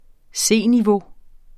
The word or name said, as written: C-niveau